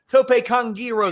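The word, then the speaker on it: tope con giro: Tope Kongiro, they call it now. We've been saying it wrong for decades.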